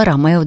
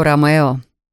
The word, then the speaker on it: Ромео: «Чао, Ромео!» Подобно Умберто и Несс, Люк произносил это имя на итальянский манер.